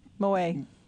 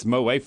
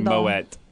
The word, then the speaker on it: Moët: So let me ask you, I've always wanted to know, is it Moët or Moët? – Moët. – It's Moët for sure. It's very English to say Moët.